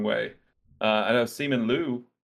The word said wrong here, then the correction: Simu Liu: Simu Liu.